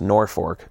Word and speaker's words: Norfolk: It's spelled Norfolk, pronounced Norfolk, Nebraska.